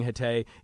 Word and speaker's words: Hatay: That area is called Hatay Province.